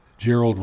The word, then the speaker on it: Gerald: The other guy I really like listening to is Gerald Celente, C-E-L-E-N-T-E. Gerald is his first name. Gerald rhymes with Harold.